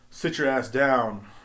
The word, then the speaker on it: Citra Ass Down: And that's how it's spelled. It's spelled Citra Ass Down. It's an IPA. It's spelled C-I-T-R-A, then the word ass, and then down.